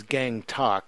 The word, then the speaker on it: Gangtok: They don't have the highest peaks, but we can climb up to their capital city is Gangtok, is how you pronounce it.